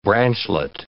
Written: branchlet

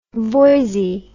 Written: Voysey

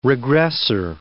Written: regressor

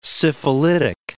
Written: syphilitic

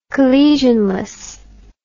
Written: collisionless

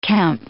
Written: Kemp

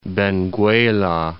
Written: Benguela

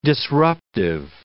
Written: disruptive

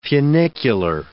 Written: funicular

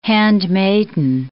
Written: handmaid

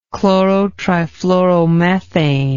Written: chlorotrifluoromethane

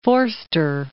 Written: Forster

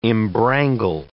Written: embrangle

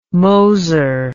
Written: Moser

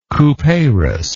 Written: Couperus

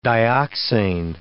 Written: dioxane

